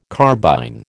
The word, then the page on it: carbine: American English